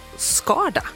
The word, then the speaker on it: Skarda: Byn hon kommer från uttalas som Varda, alltså Skarda, antar jag då.